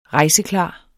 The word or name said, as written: rejseklar